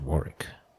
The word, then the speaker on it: Warwick: First, to get this out of the way, it's pronounced Warwick. The second W is silent. Just pretend it's another R.